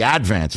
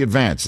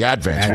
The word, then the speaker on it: Advance: It's just that you have to put the accent on the first syllable. It's the Advance. It's not the Advance. It's the Advance.